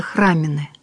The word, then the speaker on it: храмины: Правильно говорится, храмины.